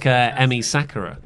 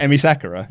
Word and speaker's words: Emi Sakura: I feel like Emi Sakura. – Sorry, I'm just waiting for VLC to. – Did I get her name right? – It's not just open if you click. – Emi Sakura? – Yeah. – Yes, you did.